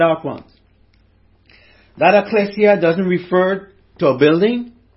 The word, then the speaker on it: ekklesia: Now, the Greek word, as we know, if you're a student of the Bible, you would know, it's pronounced ekklesia. Ekklesia.